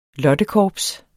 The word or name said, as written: lottekorps